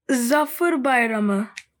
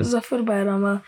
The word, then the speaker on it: Zafer Bayramı: Zafer Bayramı. – Oh, you say it so much better than I could. – Zafer Bayramı.